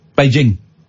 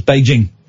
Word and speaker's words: Beijing: It's a hard J. Beijing. – Okay. – It's Beijing.